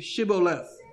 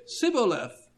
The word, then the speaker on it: Shibboleth: And if he said, nay, then they said unto him, now say Shibboleth. And he said, Sibboleth, for he could not frame to pronounce it right.